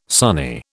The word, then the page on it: sonny: British English